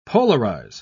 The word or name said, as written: polarize